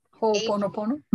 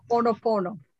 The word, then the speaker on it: Ho'oponopono: Ho'oponopono. – Say that again. – Which one? The H-O yes. word? Yeah. Yes. Ho'oponopono.